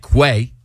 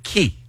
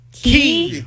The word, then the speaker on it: Quay: They look like Quay. Q-U-A-Y. – That's what I'd pronounce. – It's a nice brand. – And it's and Kelly says it's Quay. – It's Key. – Key. – Key, like K-E-Y.